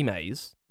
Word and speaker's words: Mays: Willie May. – Right. – No, his name is Willie Mays, but you spell it. – M-A-Y-S.